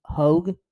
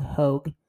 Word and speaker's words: Hogue: And then, the guy I really like on this team, Graydon Hogue, I believe is how you pronounce the last name. Not Hogg, Hogue.